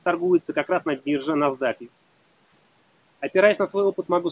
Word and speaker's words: NASDAQ: Читается как NASDAQ.